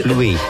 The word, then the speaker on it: pluie: Pluie. – Spell that? – P-L-U-I-E.